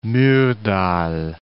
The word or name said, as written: Myrdal